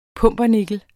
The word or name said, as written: pumpernikkel